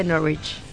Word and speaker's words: Norwich: Se escribe Norwich, pero se dice Norwich.